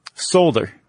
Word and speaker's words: solder: Did you say soldier? – Solder. – Solder? – Yeah. – Never heard it pronounced that way. – How do you say it? – Solder. – A solder. You gotta say that L. – I think the L silent, unless it's regional.